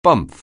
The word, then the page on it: bumf: anglais